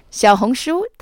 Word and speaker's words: 小红书: 小红书, that's spelled X-I-A-O-H-O-N-G-S-H-U, literally means little red book in Chinese.